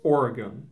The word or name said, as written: Oregon